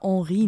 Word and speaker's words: Henri: Wir haben hier gerade noch diskutiert, ob wir ihn Henry oder Henri nennen. Aber es wird wohl französisch ausgesprochen.